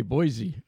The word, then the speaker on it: Boise: Bishop Soto, of course, Bishop Wiegand, of course, is from Idaho, and the Diocese of Boise, and they make sure it's spelled with an S and it's pronounced with an S.